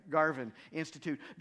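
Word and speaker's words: Garvan Institute: A scientist who participated in that experiment explains it this way: during periods, or I mispronounced it by the way, it's the Garvin Institute.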